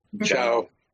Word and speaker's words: Ciao: Chow.